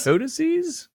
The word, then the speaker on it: codices: Codices? – Codices, I believe is how you pronounce it. It's the plural of codex,